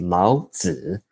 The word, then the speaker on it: Lao Tzu: And by the way, Lao Tzu, right? It's actually pronounced Lao Tzu.